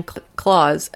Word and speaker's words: Klaus: She said, when my aunt remarried, her last name became Klaus, but extra S, but pronounced like Santa Claus. So her last name became Claus, and her first name is Candy.